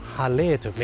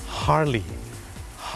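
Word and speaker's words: Harley: ฮาร์เลย์ถูกไหมครับคนไทยออกเสียงว่าฮาร์เลย์แต่เจ้าของภาษานะครับไม่ได้ออกเสียงว่าฮาร์เลย์ครับเขาออกเสียงว่าฮาร์ลีย์ฮ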